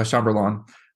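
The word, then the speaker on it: chambelán: First of all, pronounce that. What's it called? – A chambalan. Like the chambalan. – Or that's how I've always said it.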